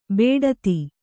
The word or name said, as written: ಬೇಡತಿ